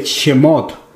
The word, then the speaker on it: Shemot: La semana pasada estuvimos iniciando el estudio de Shemot. Es así como se pronuncia en hebreo la palabra éxodo,